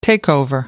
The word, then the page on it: takeover: American English